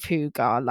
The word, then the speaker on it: hygge: My second top tip is to embrace the Scandinavian culture of coziness called hygge, which is not spelt like that. It's spelt H-Y-G-G-E, but it's apparently pronounced hygge, which is...